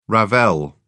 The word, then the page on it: ravel: British English